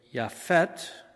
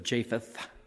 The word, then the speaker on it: Japheth: And I'm going to say Yafet instead of Japheth.